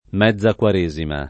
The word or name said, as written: mezza quaresima